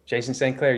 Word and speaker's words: Jason St. Clair: Jason St. Clair, you got it.